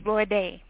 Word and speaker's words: Lorde: And then Hot 100 number one is it Lorde or I never know Lord. – How to say it, but I think it's Lorde. Lord. Oh, it is Lord.